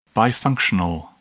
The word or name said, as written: bifunctional